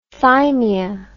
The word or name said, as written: thymia